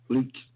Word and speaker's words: Leach: I mean, I'm sure I'm pronouncing this wrong, but how would you pronounce L-E-A-C-H? – Leech.